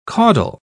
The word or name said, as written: caudal